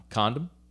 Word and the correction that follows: Condon: No. Oh, the N is silent. Yes, that's or the M is silent. Yes, it's in, an N, not an M. Inside. Yeah, I know. Just, he, Condon.